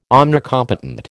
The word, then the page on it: omnicompetent: American English